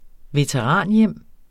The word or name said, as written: veteranhjem